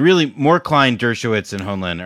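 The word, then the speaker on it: Hoenlein: We've got Malcolm Henlein. I don't know how to pronounce this fucking guy's name. – Honlein.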